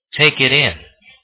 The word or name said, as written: take it in